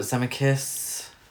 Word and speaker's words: Zemeckis: Robert Zemeckis.